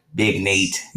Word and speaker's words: Big Nate: Big Naughty up in here? – Big Nate. – Nate? Say your name right. Nate, Naughty. Nate.